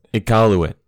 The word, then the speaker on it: Iqaluit: Iqaluit.